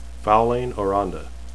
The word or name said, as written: faolain